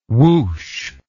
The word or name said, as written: woosh